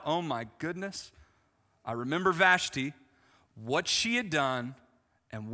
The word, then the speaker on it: Vashti: I got Logos, and I realized that I was pronouncing her name wrong. I haven't got to the other names, but I got to hers. Her name's Vashti. Everybody say Vashti. Vashti. There you go. That's her name.